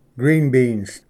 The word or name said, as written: green beans